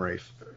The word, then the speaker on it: Ralph: Say his name, Rafe, but it's spelled Ralph.